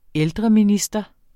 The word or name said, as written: ældreminister